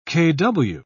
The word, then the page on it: kw: British English